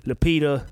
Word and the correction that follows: Letitia: Is that how you say her name? – Letitia. – Letitia Wright. Okay, cool.